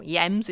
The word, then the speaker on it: hiems: And winter, I do have something to talk about because winter is H-I-E-M-S, hiems or yems if you're not pronouncing the H, which does eventually give us words like hibernate.